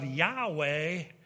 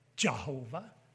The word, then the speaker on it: Yahweh: The wrong spelling Jehovah revised version, the L-O-R-D, occurs since about the year 1100. And then it offers its arguments in favor of Yahweh as the correct and original pronunciation. How about that? They admit it. They know it's not Jehovah or Yahovah or Yahovah.